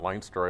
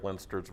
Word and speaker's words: Leinster: It's pronounced Leinster, not Leinster.